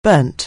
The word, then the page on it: burn: anglais